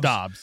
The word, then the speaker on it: Dobbs: Is it Dobbs or Dubes? Dobbs. – It's Dobbs. Yeah, it's Dobbs.